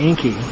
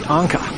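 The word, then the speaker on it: Encke: and it's E-N-C-K-E. I always called it Enki, but it's actually Anka,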